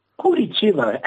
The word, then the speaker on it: Curitiba: Pues mira, este tío fue campeón del mundo de capoeira en 2004 en Curitiba. ¿Lo he pronunciado bien? – Curitiba, ahí es.